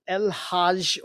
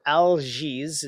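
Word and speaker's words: Algiz: Yeah, in the Elder Futhark system, the rune called El Hajj or Al-Jiz, it can be pronounced either way in fluctuation.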